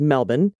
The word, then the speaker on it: Melbourne: My partner, who is from L.A., and I live near Melbourne, which of course is pronounced Melbourne.